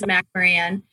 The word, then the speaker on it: McMoRan: It just looks a little different than it sounds, but it is McMoRan. The Mac, Mo, and Ran are for three people who were the founders of the company.